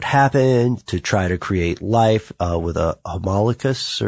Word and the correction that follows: homunculus: or am I pronouncing that correctly? – Homunculus? – Yes.